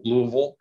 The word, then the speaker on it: Louisville: I think I pronounced that correctly, Louisville.